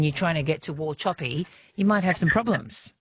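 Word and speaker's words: Wauchope: On the text line, a place name that's tricky for most, or well, for me anyway, until I found out the pronunciation, is Warhope. – Warhope. Oh, yeah. – Warchoppy. – Yeah, Warchoppy. Warchoppy.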